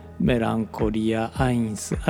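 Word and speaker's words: Melencolia I: メランコリア 1」の内容です今は「メランコリア 1」と読みましたが、まあ、本来はドイツ語なので「メランコリア・アインス」あるいは「メランコリアエアストと読むのが正しい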